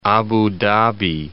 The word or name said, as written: Abu Dhabi